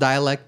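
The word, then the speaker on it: Dälek: It's pronounced dialect, but it's spelled D-A-L-E-K.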